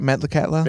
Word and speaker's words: Metlakatla: So Metlakatla, right? Did I say that right? Metlakatla? – Yeah.